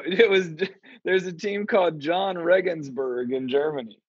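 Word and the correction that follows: Jahn: I think it's pronounced Jan Regensburg, but yeah, that it's just a dude's name.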